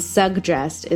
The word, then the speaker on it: suggest: So I say suggest, but I would just type it out as two J's. And then suggest is with the hard G, then the J.